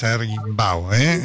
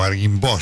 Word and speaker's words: Arguimbau: El padre Lucas Arguimbau, o Arguimbo, si lo pronuncias en francés, un cura fenomenal, realmente queridísimo por todos, con mucho trabajo con los chicos jóvenes en colegios y demás, ¿no?